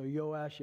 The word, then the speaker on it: Joash: You know, in Hebrew, the J would be most likely pronounced like a Yah. So, Joash, Jehoash. But for the sake of just us all understanding together, let's use our good old Canadian pronunciation and just call him Joash.